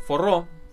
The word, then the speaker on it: Forró: Forró. Forró es una música... Ah. Sí. De hecho, se pronuncia como la R entre una R y una G. Forró. Una cosa así.